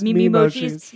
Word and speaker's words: Memoji: Am I saying that right? – Yes, Memojis. Yeah, I have a little bit.